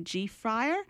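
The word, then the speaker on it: G-Phyre: Today he has his own clothing line called G-Fryer, and that's spelled G.PHYRE. And in 2008, he released a memoir, The Adventures of Grandmaster Flash, My Life, My Beats.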